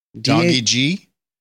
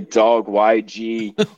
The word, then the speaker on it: dawgyg: D-A-W-G-Y-G. – Doggy G? – You're the first person that actually pronounced it right on their first try. Most people say dog YG or something like that, but it's doggy G.